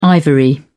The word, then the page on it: ivory: British English